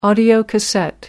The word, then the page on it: audiocassette: British English